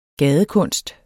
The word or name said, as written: gadekunst